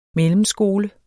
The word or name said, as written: mellemskole